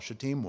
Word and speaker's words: shittim: I remember the first time I went through that section, and David said something to me afterwards about, I was really glad that you pronounced that right, shittim wood, because that's how it is in the Hebrew.